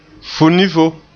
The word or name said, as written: funifo